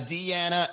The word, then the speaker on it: Deeyanna: D-E-E-Y-A-N-N-A. You spelled it like Diana. D-I-A-N-A. It's not my fault. Don't get mad at me because you didn't spell it the way you want it to be pronounced. It's not my fault. Hey, it's Joanna. What's up, guys? I'm Diana. Oh, we got Deanna and Diana. Oh, the details. Say it right. Say my name right. How are you going to recognize me from all the other Dianas if you don't call me Deanna?